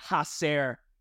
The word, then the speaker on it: hacer: It's almost like in Spanish, the H is silent. Hacer, but side note.